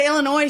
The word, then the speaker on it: Illinois: That drives Illinoisans crazy, though, when people say the S at the end.